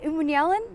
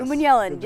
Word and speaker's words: Umanmielen: Umanmielen? – Yes. Umanmielen.